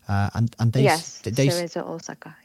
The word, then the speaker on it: Cerezo Osaka: But, you know, Takumi started his career, Cerezo Osaka, I think I've hopefully pronounced that right. – Cerezo Osaka.